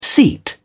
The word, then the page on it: seat: British English